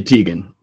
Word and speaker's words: Teigen: T-E-I-G-E-N. Do you know her name? – I thought it was Chrissy Teigen.